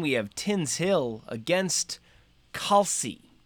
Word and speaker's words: Tinshill: Tinshill, pronounced Tinshill, is a district in Leeds, 4 miles north of Leeds city center, West Yorkshire, England.